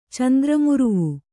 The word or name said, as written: ಚಂದ್ರಮುರುವು